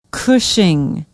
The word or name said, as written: Cushing